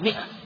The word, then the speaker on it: مائة: وقد صنف أبو بكر الأجري وكان من العلماء الربانيين في أوائل المائة الرابعة المائة، ولو كتبت بالألف تنطق مائة، المائة الرابعة ولو كتبت بالألف في أوائل المائة الرابعة، وهذا الذي نسمعه أحيانا من بعض الناس ماء غلط، وإنما هي مائة، ولو كتبت بالألف ممدودة تنطق مائة، وإن كان الصواب الذي يترجح عندي أنها تكتب على كرسي موصولة بدون ألف مائة.